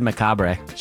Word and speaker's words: macabre: How do you say that? – Macabre. – Then why is there an R and an E? – Because the English language is weird.